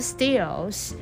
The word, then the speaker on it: steel: steel. What is steel? S-T-E-E-L. Please pay attention to the T. T here we pronounce the steel. What is steel? S-T-E-E-L